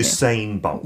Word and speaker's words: Usain Bolt: Usain Bolt.